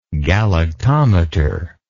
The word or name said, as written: galactometry